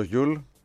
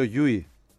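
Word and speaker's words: Llull: Να ξέρεις ότι το Γιούλ το λέω στραβά, παρότι είμαι ψήρας με τα ονόματα. Δεν το προφέρει έτσι ο Γιούλ. Το λέει Σέρχιο Γιούι.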